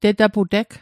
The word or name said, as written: tetapu'teg